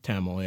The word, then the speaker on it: Tamil: Tamil. – Yeah, that's pretty good. There's like a Tamil, is how people say it, and I never ever say it.